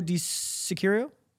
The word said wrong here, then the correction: Di Chirico: I think that's wrong. – I think that's wrong. Shout too. out Shay for this one, by the way. Di Chirico. That's what it is.